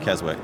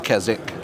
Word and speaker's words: Keswick: And it's pronounced Keswick because it's a British thing. So you Americans always, you know, butcher the name. – Yeah, we butcher everything. – You put the W in there.